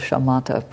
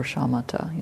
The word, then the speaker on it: shamatha: So that is what's called the kind of pair with Vipassana practice is called shamatha practice, or shamatha. You'll hear it pronounced that way.